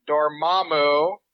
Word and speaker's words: Dormammu: To begin my perspective on the villain, Caecilius, yes, one could argue that Dormammu, did I say it right? – Yep.